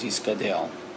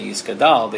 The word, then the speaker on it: Yiskadel: And Rashi felt, based on some psukim in Yechezkel, that the correct way to pronounce this was Yiskadel. Yeah, in some German minhagim not only say Yiskadal, they say Tiskabal.